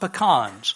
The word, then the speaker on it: pecan: pecans. Now, in those years, you could not buy pecans on the island of Mindanao. By the way, pecan is the correct pronunciation. I know I'm from Texas.